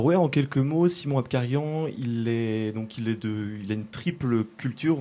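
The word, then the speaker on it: Abkarian: L'ai-je bien prononcé ? – C'est bien Apkarian, oui.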